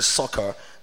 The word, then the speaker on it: Sychar: That's what I've been calling it. I think it's actually Sychar, the real pronunciation.